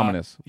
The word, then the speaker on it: Ominous: I keep saying it wrong. Om ominous. I keep. I will say omnious every time. Uh, ominous.